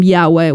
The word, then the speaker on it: Yahweh: More than 10 times, actually 10 times, the name Yahweh, which is Jehovah, that now we know how to pronounce from the Hebrew,